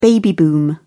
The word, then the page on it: baby boom: British English